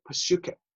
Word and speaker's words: psyche: 那另外一个字也代表生命，那叫做 Psyche，那就跟英文的 psychology 那个字的字根从这里来了，只是我们英文的 psychology 的时候，那个 p 在前面不发音，就是 psychology。希腊文的 p 要发音，就是 psyche。